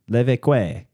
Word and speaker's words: Levesque: I feel like it's Levesque, and every time I see him, I'm like, I wonder if his family made the Levesque Tower. – Levesque. That's it.